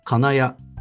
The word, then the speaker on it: 金谷: そして3期、金谷は名古屋のアクセントでしたが、こちらは渋谷のアクセントでいいんです。金谷なんですね。